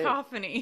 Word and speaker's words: cacophony: Oh, what is the correct way to say it? – Cacophony. – Cacophony.